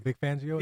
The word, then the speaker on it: Fangio: Is that how you say his name? – Yeah, Vic Fangio.